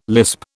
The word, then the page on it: lisp: British English